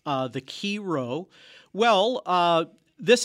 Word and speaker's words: Chi Rho: And the first one I want to talk about is the chiro. That's spelled C-H-I-R-H-O, chiro.